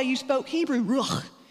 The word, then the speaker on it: ruach: The word is ruah. It's actually, if you pronounce it, this is going to sound lovely in a microphone. If you pronounce it with a Hebrew guttural, it's called ruach. Go ahead and say that with me, ruach.